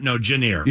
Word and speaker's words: Jahnair: Jahnair. – Yeah. Jahnair, The Y is a J. – Closer to a J than a Y is.